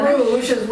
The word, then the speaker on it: Bruges: Depending on which language you want to pronounce it in. – Right, one's French and one's Bruges would be the French and the Flemish would be Brugge.